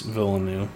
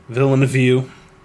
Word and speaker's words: Villeneuve: It's Villeneuve. – Villeneuve.